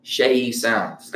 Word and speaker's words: SeyiSounds: But today we have songwriter, rapper, artist, music lover, hip hop culture follower, dreamer, Far Rockaway, Queens raised, SeyiSounds.